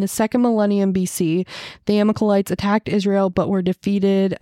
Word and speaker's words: Amalekites: Amalekites? – Amalekites? – Wait, where? – Verse 29, the Amalekites, I think that's it, Amalekites.